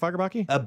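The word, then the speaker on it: Fagerbakke: It's Fackerbacky, right? Am I saying it right? Fackerbacky? – Bill Fackerbacky is Patrick Starr. Very yes. good.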